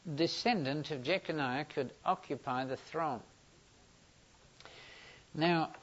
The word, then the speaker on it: Jeconiah: J-E-C-O-N-I-A-H.